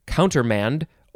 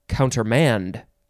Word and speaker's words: countermand: It is countermand, M-A-N-D, and you can also emphasize the last syllable, so it could be countermand or countermand.